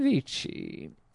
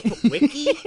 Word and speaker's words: vici: Because, vidi, vini, vici. – Because, Vinny, actually, it's pronounced Winnie Winnie Wiki. It's a hard C, and there was no V sound.